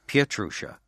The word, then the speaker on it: Pietrusza: Pietrusza. It's really three syllables. – Pietrusza. – Yeah. – Is that because it's Polish? – It's Polish, yeah.